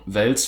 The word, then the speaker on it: Wels: Ja, ist so ein bisschen so wie, dass ich einfach mein Leben lang Wels falsch ausgesprochen habe, weil alle Leute in meiner Familie den Fisch Wels ausgesprochen haben. – Wie kommt man darauf, den Fisch Wels auszusprechen? – weil nichts dagegen spricht, dass es eh langgezogen wird, rein von den Regeln der deutschen Sprache.